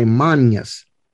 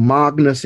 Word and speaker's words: magnus: Um, by the way, if you pronounce that in Catholic Latin, you say Magnus. So Magnus in classical Latin, Magnus in, Cla- in Catholic Latin.